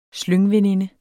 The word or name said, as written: SLYNGVENINDERNES